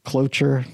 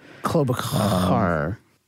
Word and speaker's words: Klobuchar: Klobuchar. That's actually not how you say it. I just thought it was funnier to pronounce it the way I did.